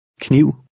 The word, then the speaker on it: kniv: The first two letters are pronounced separately. – Kniv.